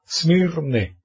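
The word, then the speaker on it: Smirne (Σμύρνη): En turco, Izmir es la contracción del antiguo nombre griego Smurne. Smurne, esta es la capital, el capital Smurne. S-M-U-R-E-N-R-E, Smurne. Claro, o sea que esto, la U, hoy en día se pronuncia como Y, Smirne, ¿ok?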